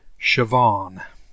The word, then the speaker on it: Siobhan: Is it Siobhan? – Siobhan. – Siobhan. Okay. Yes. It's a silent O.B.? – The S-I-O or S-I is pronounced like S-H and B-H-A-N is pronounced like a V. Siobhan.